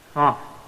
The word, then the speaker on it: Kaf: Okay, how do we pronounce this one? Kaf.